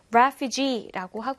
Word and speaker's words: refugee: refugee라고 하고요. 강세가 앞에도 있고요. 뒤에도 있어요. 그래서 refugee. refugee라고 발음된다라는 거 챙기면 좋겠습니다.